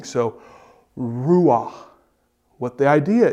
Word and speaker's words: Ruach: It's this, Ruach. R-U-A-C-H. Ruach. Ruach. Okay?